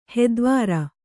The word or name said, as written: ಹಿತವಾರೆ